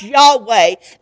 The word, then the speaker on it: Yahweh: The name Yahweh, Hebrew word Lord, and it's pronounced Yahweh, and it's built on the word I am.